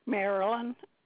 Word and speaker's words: Marilyn: Marilyn, M-A-R. I-L-Y-N. – Thank you. And your last name? – Dupree. D-U-P-R-E-E.